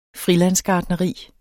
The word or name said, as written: friland